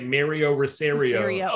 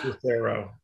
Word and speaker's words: Rosario: You sound like my old principal who'd say Mario Rosario. – Oh, gosh. No. Well, there's no I in the last name. It's just Rosario.